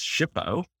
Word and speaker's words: SHPO: Often go by the acronym that is SHPO, pronounced SHPO.